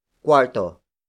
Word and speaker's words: kuwarto: It is the direct object marker, indicating that the next word is what you're going to clean, and that is quarto. Quarto. Quarto. Quarto is spelled as K-U-W-A-R-T-O.